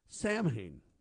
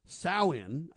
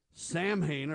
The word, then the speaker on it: Samhain: Now, that's the wrong pronunciation. They say it's an ancient Celtic festival marking the end of the harvest season, the beginning of winter. Pronounce Samhain, I think is how you say it.